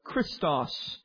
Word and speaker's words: Christos: The term Christ comes from the Greek word Christos. That's how it's pronounced in the Greek.